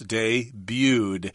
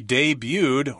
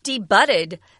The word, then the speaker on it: debuted: So it's kind of interesting, because then this word only has two syllables, debuted. She debuted, or came out for the first time, in a comic book back in 1984. – Yeah, be careful. Don't say debuted. That would be incorrect.